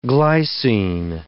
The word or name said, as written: glycine